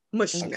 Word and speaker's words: Mushnik: What's the shopkeeper's name? Mr. Munchnik. – Munchnik. – Mushnik. – Okay, Mr. Yeah. Mushnik.